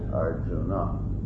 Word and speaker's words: Arjuna: But here we have a little poetic license, but Arjun, in Hindi they say Arjun, whereas we would say in Sanskrit Arjuna.